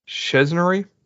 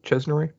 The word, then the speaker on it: Czerny: Isaiah Morales, Ving Rhames returning, Rob Delaney, let's see here, Henry, I'm gonna try and get this right, I think it's pronounced Chesnery, C-Z-E-R-N-Y, Chesnery, as returning as from his original role from the very first Mission Impossible as Eugene Kittredge, and let's see here.